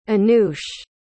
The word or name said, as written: anoush